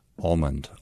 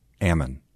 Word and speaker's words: almond: I have to stop myself to say it correctly. Almond. Almond. – Almond? – Yeah, almond. We don't say that. – What do you say? – We grow them there, so we say Ammon.